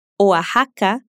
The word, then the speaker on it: Oaxaca: Outras palavras que mantiveram a escrita com X, mas que são pronunciadas como J, são Oaxaca, Terras.